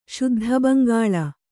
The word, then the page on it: ಶುದ್ಧಬಂಗಾಳ: Kannada